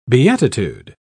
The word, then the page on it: beatitude: British English